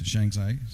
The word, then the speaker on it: Shanxi: Weinan, and is that the Shanxi province? – Yes, Shanxi.